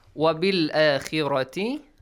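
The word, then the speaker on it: Wabil akhirati (وبالآخرة): Wabil akhirati, khi. The letter kha is pronounced lightly here, okay? Because it has a kasra sound. Wabil akhirati, rati. Look, heavy, light, and then heavy sound.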